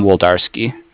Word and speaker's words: Wlodarski: It's Sam Woldarski.